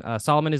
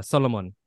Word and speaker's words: Solomon: Also, it's there's also a different pronunciation to my name because my family is Ethiopian. Solomon is like a direct translation, but it's pronounced Solomon. My parents and my family call me Solomon.